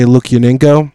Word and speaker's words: Lukyanenko: Secondly, I've been super into the Night Watch series, the books by Sergei Lukyanenko.